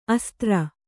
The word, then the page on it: ಅಸ್ತ್ರಿ: astri